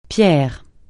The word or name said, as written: pierre